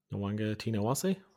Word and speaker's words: Nawaqanitawase: Wer sagst du es mal? Nowange Tinawase. Boah, super.